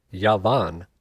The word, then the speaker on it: Javan: Javan, which is pronounced Yavan, were the Ionian Greeks and peoples of the coasts of the Mediterranean, Spain, and Portugal.